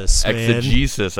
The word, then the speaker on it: exegesis: Well, first of all, we have to notice the new word you threw out there, exegesis. – I know. Exegesis. I didn't say it right. – You just I didn't notice that. I just like, that's a new word. – That is a new word. – You're hitting all the Salty Pastor listeners. – I looked it up, and then apparently I did not. I should have asked you how to pronounce it better. – No, exegesis is a great word.